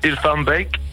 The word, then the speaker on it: Hilvarenbeek: Can you pronounce Hilvarenbeek? – Is it Hilvarenbeek? – Hilvarenbeek, yeah, pretty good.